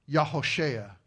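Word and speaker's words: Yehoshua: Or to give you the Hebrew pronunciation, Yahoshea is his name.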